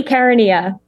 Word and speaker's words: Caronia: I always say your name wrong, so I'm just going to say it here. Say your last name. – Hayley Caronia.